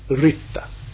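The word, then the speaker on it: ṛta: Ritta, R-I-T-A. Or if you want to be very accurate, R with the dot underneath it, which is pronounced Ri and T-A. Ritta. Our word right in both senses, R-I-T-E as well as R-I-G-H-T, is derived from Ritta.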